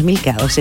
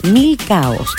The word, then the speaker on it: milcaos: Sí, se dice milcao. No es milcados. Es milcaos. Así se pronuncia.